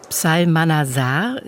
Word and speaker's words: Psalmanazar: Der erschien 2009 und der Titel ist Die Lichter des George Psalmanazar. Ist das richtig ausgesprochen? – Das ist richtig ausgesprochen.